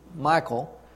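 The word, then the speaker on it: Michal: I think it's pronounced like Michael without an emphasis on the E, but so I'll just call her Michael.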